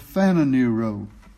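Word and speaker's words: phananero (φανερόω): I did correct it toward the end of the message, but I kept mispronouncing the word because I kept calling it panaruo instead of phananero. In other words, it should be an F instead of a P.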